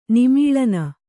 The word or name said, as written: ನಿಮೀಳನ